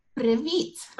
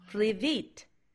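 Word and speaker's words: привіт: How do you say hi in Ukrainian? You say privit. – Privit.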